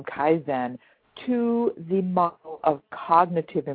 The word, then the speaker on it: Kaizen: now tell me, what does Kaizen. Am I saying that right? Kaizen? – Have to no. – Is it Kaizen? – Kaizen. Well, it is. Sheila, that is a Japanese word. It's Kaizen. And I make a big point of referencing Kaizen in my book. And since it is derived from the Japanese, there are alternate pronunciations for that. I've heard a number of things, so don't feel bad. I pronounce it Kaizen.